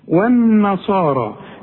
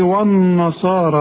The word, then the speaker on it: النصارى: خلاص، والذين هادوا خدتها انت دلوقتي، هادوا كلها مرققة، والنصارى يا ريتك تنطقها زي ما انا نطقتها، انما انت هتقول لي والنصارى والنا، شوف وا والنا، غلط، ده انت لسه قايل دلوقتي والذين، الواو دائما مرققه، هنا ليه فخمتها لي قلت لي وا والنصارى؟